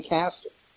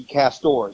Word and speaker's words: Castor: Like I said, I think it's going to be up to Jane Castor. Or it should be Castor, excuse me. I believe that's the correct pronunciation.